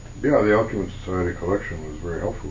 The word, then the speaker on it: Alcuin: The end of it, you list the Alcuin, am I pronouncing that correctly? – Alcuin.